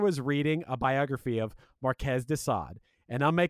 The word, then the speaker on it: Marquis de Sade: Is it Marquez de Sade? – It's Marquez de Sade.